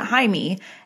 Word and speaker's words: Jaime: She pronounced it Jaime, as in Spanish, which is something that she would have had no way of knowing at the time. So it looks like Jamie, but oh, okay. – It's pronounced Jaime.